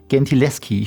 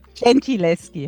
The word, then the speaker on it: Gentileschi: Bestes Beispiel, Sie haben vor kurzem auch erst ein Buch drüber geschrieben, die Artemisia Gentileschi. Ich hoffe, ich habe es richtig ausgesprochen. – Nicht ganz. Okay. Gentileschi heißt.